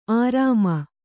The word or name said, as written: ಅರಮೆ